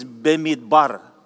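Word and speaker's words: bemidbar (במדבר): At pag ito po ay pronounced, ang pronunciation po dito is bemidbar. Hebrew word bemidbar from the letters beth, mem, dalet, another beth, and then resh.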